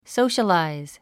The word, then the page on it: socialize: sóuʃəlàiz; ソウシャラァイズ